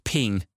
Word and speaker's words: PNG: The file format PNG is pronounced ping.